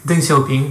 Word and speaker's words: Deng Xiaoping: mais Deng Xiaoping. – Deng Xiaoping, ouais, voilà.